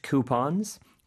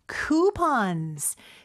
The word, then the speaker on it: coupon: 근데 쿠폰은 발음이 사실 두 가지가 있어요. – 네. – Coupon 혹은? – Coupons. – Coupon이라고도 할 수 있습니다. 근데 우리나라에서는요. 쿠폰으로 많이 알고 있고 사실 우리가 수능 녹음을 할 때는 꼭 쿠폰으로만 발음을 해야 되는데 실생활에서는 쿠폰이라고 하는 경우도 있으니까 여러분 가서 당황하지 마세요. 두 가지 발음이 있습니다.